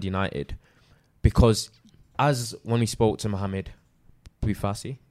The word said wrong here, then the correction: Bouhafsi: is that Bouhafsi. Right? – Bouhafsi. I've never pronounced his surname correctly, but a suave guy, nice guy though.